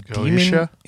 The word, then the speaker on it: Goetia: Together, they perform the ritual of Goita. I think I'm saying that right. This refers to an evocation of Goetia.